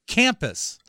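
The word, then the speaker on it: Kampus: Campus, like campus. A college, but with a K, right?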